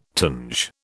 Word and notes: tinge: British English

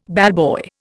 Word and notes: ballboy: Indian

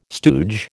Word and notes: stooge: American English

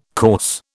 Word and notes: course: British English

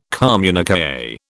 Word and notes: communiqué: British English

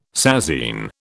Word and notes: sasine: British English

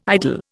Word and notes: idle: Indian

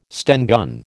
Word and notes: Sten gun: American English

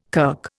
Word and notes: kirk: Indian

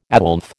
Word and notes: Aslef: American English

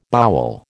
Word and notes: bowel: American English

